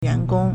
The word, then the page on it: 员工: yuángōng